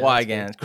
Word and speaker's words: Weigand: Yeah, Weigand.